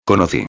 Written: conoci

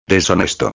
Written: deshonesto

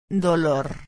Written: dolor

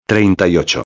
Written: 38

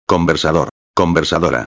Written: conversador-a

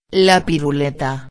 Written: la piruleta

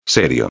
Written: serio

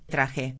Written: traje

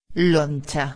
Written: loncha

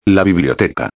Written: la biblioteca

